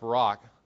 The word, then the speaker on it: Barak: By the way, his name is actually pronounced Barak.